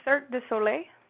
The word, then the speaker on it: Cirque du Soleil: Circus du Soleil. Is that how you say it? – Circus. Uh, it's Cirque du Soleil. Cirque du Soleil. Mm-hmm. Totally mm-hmm. had that wrong. Okay. It's French.